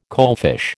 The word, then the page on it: coalfish: American English